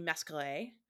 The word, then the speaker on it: Masqueray: and maybe it's Emmanuel Louis Masqueray. – That but sounds the, good.